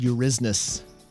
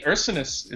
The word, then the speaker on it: Ursinus: The first attempt at pronouncing it, the PA guy said Eurysnus. – Oh, well, yeah. I mean, Ursinus, it seems like a legitimate, never seen that before. It seems legitimate the way it's spelled. – Ursinus, at least that's the way it's spelled. Eurysnus is like saying Allegheny.